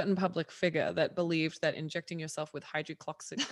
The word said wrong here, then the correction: hydroxychloroquine: Hydrocloxin chlorophin uh, hydroxychloroquine.